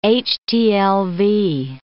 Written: HTLV

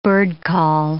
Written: birdcall

